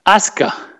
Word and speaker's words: Asuka: Asuka. It's easy. – Osaka. – It's Asuka. – Asuka. All right, Asuka. Imagine there's no U in there.